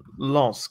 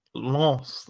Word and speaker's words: Lens: But it's like the nasal Lens, like Lens, not lens. Lens.